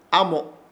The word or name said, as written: AMO